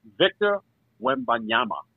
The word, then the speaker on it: Victor Wembanyama: Victor Wembanyama.